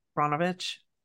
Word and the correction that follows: Brnovich: just Yeah. – we're missing a vowel. – Burn. Brnovich. – Brnovich.